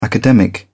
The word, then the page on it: academic: British English